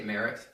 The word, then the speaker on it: Maret: I say Maret.